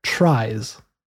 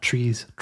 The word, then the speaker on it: tries: And also related to trees, but not exactly the same, is what we call tries. And that's spelled T-R-I-E-S, so not trees, tries.